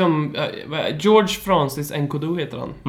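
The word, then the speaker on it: N'Koudou: Nu kan jag inte uttala hans namn. Kevin. – Ja, uh, Nkodu. Nkodu, ja precis.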